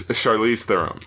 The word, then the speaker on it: Charlize Theron: It's not Charlize. – Charlize. – It's Charlize Theron.